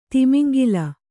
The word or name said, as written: ತಿಮಿಂಗಿಲ